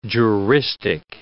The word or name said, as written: juristic